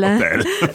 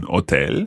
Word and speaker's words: hotel: We say un hotel, mm-hmm. un albergo. – But just watch with hotel in Italian, you don't have to pronounce the H, so it's hotel.